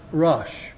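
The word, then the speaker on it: Rosh: Now the word there, chief prince, the Hebrew word is pronounced Rush.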